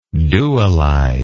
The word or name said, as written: dualization